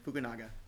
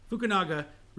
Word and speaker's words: Fukunaga: Fukunaga. – Fukunaga.